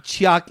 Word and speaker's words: Tsioque: Chalk, it's spelled T-S-I-O-Q-U-E. Chalk. – Chalkway.